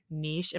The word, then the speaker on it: niche: you talked about like finding your niche. Am I saying that right? Niche? – Yes.